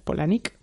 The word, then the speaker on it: Palahniuk: Nos pusieron en una entrevista conjunta con más gente todos a competir por hacerle pues preguntas a Chuck Palahniuk, que de hecho se pronuncia Polanik, pero bueno.